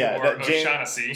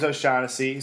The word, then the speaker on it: O'Shaughnessy: In round five, tight end Oshag Hennessy. Is that how you say it? – Yeah, I think so. I think it's James Oshag Hennessy O'Shaughnessy. – James O'Shaughnessy.